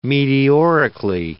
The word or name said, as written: meteorically